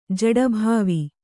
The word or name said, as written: ಜಡೀಭಾವ